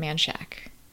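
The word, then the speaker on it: Manchaca: And then there's this other weird thing where there's a road that's spelled Manshaka, but everyone just calls it Manshack.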